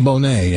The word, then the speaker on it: Bonet: Was it Lisa Bonet? – Bonet, yeah.